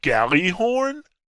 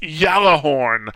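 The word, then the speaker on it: Gjallarhorn: He's like, he got a Galahorn? He didn't know how to pronounce it. You know, and I'm pretty sure there was some nerd in the background saying, it's pronounced Yallahorn.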